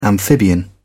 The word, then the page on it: amphibian: British English